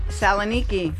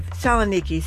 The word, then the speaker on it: Saloniki: Saloniki. – Saloniki.